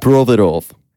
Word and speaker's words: Provorov: Provorov, I think, is how you say his name. – You gotta say it with a Russian accent. – Ivan Provorov. – Yeah. – Yeah, just like that.